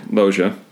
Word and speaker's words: Loggia: Several days earlier, producer Dean Devlin accidentally suggested to Loggia, or how do you pronounce it? – Loggia, that he watch Airplane 1980 for inspiration when he actually intended to suggest Airport 1970.